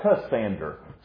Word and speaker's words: Cassander: Cassander, C-A-S-S-A-N-D-E-R.